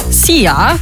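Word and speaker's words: Szia: Wird einfach ausgesprochen wie unser Energy Star Sia. – Sia, easy.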